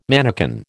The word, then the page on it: mannikin: American English